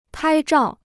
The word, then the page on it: 拍照: pāi zhào